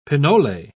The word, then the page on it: pinole: British English